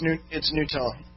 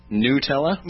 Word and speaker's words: Gnutella: It's called, I think it's probably pronounced Nutella. Gnutella. – No, it's Nutella. – Nutella? – Nutella. – Okay. – Yeah, the G salad.